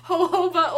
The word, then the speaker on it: jojoba: How do you say it? – Jojoba. No, I like it way better. I'm going to say that from now. Jojoba. It's jojoba.